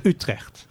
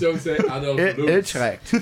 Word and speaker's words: Utrecht: And most people pronounce the city Utrecht wrong because it's Utrecht.